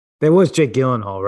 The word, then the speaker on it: Gyllenhaal: It's Gyllenhaal.